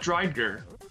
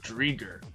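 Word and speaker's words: Driedger: And my last player is a goalie by the name of Chris, and I almost said this wrong, Chris Drieger. Not Drieger, Drieger.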